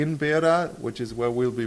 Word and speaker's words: Beira: This is the biggest city in that area, is Beira, is what it's pronounced in Portuguese.